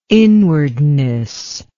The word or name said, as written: inwardness